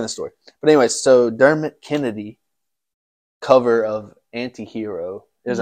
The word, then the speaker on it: Dermot: Check out Dermot Kennedy or Dermot Kennedy's. – No, Dermot. He said he pronounces it Dermot. – I thought it was Dermot, like Kermit.